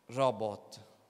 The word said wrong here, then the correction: Rehobot: no robot, sarà chiamato Ribot, come si, si pronuncia, non lo voglio sbagliare, ve lo leggo. Questo posto, sto leggendo dal Genesi 26, sarà chiamato Rehobot, scusate, Rehobot, che significa l'Eterno ci ha messo a largo e noi prospereremo nel paese.